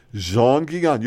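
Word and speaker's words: Jean Gillon: a mid-century chair made in Brazil by Jean Guillon. – Jean Guillon. You pronounced it perfectly.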